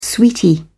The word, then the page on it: sweetie: British English